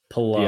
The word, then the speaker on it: Palou: It's Palou.